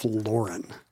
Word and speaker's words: Lauren: Yes, that's a hyper-correction because you expect a fashion guy to have a fancy Euro-sounding name. It's just Ralph Lauren.